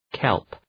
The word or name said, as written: kelp